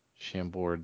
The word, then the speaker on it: Chambord: Chambord. – Chambord.